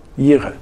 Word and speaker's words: Jireh: Habe ich das richtig gesagt, Christian? Jireh. Gott sorgt vor.